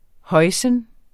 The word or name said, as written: hoisin